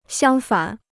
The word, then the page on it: 相反: xiāng fǎn